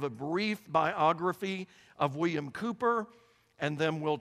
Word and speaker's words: Cowper: Now, if you're looking at the screen, you're saying, wait a minute, that's William Cowper. No, it's how it's pronounced. Cooper is the correct pronunciation.